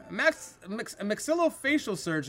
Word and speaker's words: maxillofacial: MAXILLOFACIAL. Surgeon. Pronounce this professional title. Maxillofacial surgeon. – That is correct.